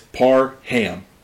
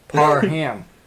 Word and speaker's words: Parham: People are asking, how do you say his name? He tweeted out, it's Parham. – Parham. – Like, he's going ham on this on this defense.